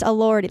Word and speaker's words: Elordi: It's pronounced Elordi, like, oh Lordy.